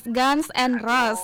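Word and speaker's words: Guns N' Roses: Bacanya bukan Gun. – Oh, Guns. Guns and Roses.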